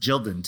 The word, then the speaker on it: Gildan: It's pronounced Gildan too. I saw it on a commercial. – Is it really pronounced like, Gildan? – Yeah.